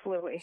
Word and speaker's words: Louis: Louis as in Louis? – Yes. Louis, Prince Louis. It's pronounced Louis.